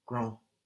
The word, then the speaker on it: grand: Le garage est trop grand. Le garage est trop grand. Good, now we don't hear the final D. No, we get G-R-A-N-D, and that D is silent.